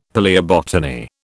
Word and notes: palaeobotany: British English

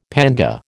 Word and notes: panga: American English

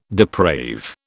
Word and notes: deprave: British English

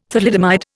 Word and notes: thalidomide: Indian

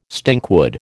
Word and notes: stinkwood: American English

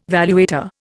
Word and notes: valuator: Indian

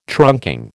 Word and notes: trunking: American English

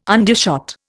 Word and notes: undershot: Indian

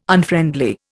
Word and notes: Unfriendly: Indian

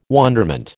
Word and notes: wonderment: American English

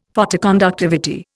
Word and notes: Photoconductivity: Indian

